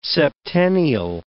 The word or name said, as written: septennial